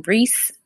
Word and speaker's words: Reese: It's spelled A-L-E-C. H-I-A, last name Reese, R-E-E-S-E.